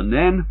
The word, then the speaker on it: Nen: The Yar is a Norfolk river which falls into the sea at Yarmouth, and the Nen, pronounced also with a short vowel, flows into the Wash.